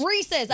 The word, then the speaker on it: Reese's: They're not Reese's. They're Reese's. – Reese's. I know. I make that mistake all the time. – No. Yeah, it doesn't rhyme with feces.